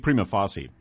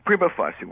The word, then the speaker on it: prima facie: I've always said prima facie. – Prima facie works for me.